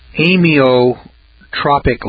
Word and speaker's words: amyotrophic: Now, there's a disease here that's called Lou Gehrig's disease, and it's called amyotrophic lateral sclerosis. It's spelled A-M-Y-O-T-R-O-P-H-I-C.